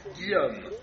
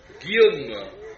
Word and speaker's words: Guillaume: Guillaume. Guillaume.